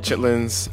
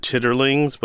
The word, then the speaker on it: chitlins: With or without Chitlins, I'm Gene Demby. – I'm Shereen Marisol Meraji. – Be easy. – Peace. – Ode to Chitlins. Note it's spelled C-H-I-T-L-I-N-S. Not chitterlings, but chitlins.